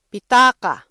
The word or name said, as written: pitaka